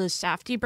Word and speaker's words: Safdie: I feel like, especially like the directors of Uncut Gems, the Safdie brothers, should I say that right? – Yes.